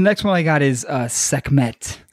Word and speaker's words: Sekhmet: It's like Sekhmet. – Okay. – Yeah, Sekhmet.